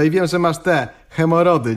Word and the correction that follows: hemoroidy: nie? – Chyba hemoroidy. – No właśnie, czyli jest coś na rzeczy, skoro wiesz, jak to się wymawia, nie? – Tak, Józef Siekierczak wstydził się swojej choroby.